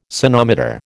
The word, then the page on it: sonometer: American English